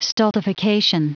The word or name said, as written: stultification